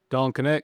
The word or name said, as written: Dalton Knecht